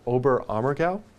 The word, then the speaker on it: Oberammergau: and he is leading a tour to Oberammergau, did I pronounce that correctly? – Correct.